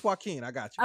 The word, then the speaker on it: Joaquin: You said Joaquin? Joaquin playing the Joker? Yes, that's exactly who he is.